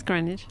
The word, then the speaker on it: Greenwich: Did I say it right? – It's Greenwich. – Greenwich? – Yes. – Greenwich. – Yeah. The W is silent, but that's okay.